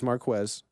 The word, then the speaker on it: Marquez: It's Marquez.